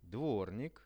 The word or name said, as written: dvornik